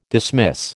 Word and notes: dismiss: American English

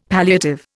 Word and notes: palliative: Indian

